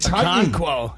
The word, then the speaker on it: Okonkwo: I got Okonkwo. Oh, I don't even know how to pronounce it. – Okonkwo, yeah.